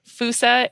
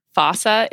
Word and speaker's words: fossa: So Fusa is often also pronounced in the Malagasy way, just because of the way that they use their pronunciations. Fossa is also a totally acceptable way to say it.